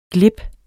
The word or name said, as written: GLIBEN